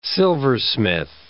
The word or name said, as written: silversmith